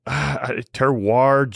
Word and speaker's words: terroir: It is T-E-R-R-O-I-R, terroir.